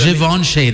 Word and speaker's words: Givenchy: Givenchy. That's how you say it.